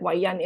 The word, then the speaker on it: Waiyan: So my Chinese name is Waiyan, Waiyan, English, but Waiyan in like how you say it.